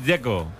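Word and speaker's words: Džeko: DZ, ¿cómo se pronuncia? – Dzeko. Bruno Dzeko. Dice que es Dzeko. – Como tirando a J, Dzeko o algo así.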